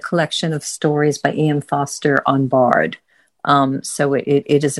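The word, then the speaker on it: Forster: It's F-O-R-S-T-E-R. – Yeah, and that's it. Foster.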